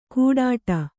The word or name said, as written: ಗುಡ್ಡ